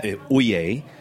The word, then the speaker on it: ouillé: you're looking for a Jura Chardonnay that says O-U-I-L-L-E on the label. – O-U-I-L-L-E. – E, pronounce that? – Ouillé. But the translation, Oye, not Oye.